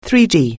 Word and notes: three-D: anglais

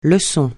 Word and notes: lèche-vitrines: francais

